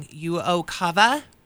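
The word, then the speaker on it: UOCAVA: So, the first thing that folks need to know is that, I'm going to say it wrong, Uokava? – Yes. – Did I say it right? – You did.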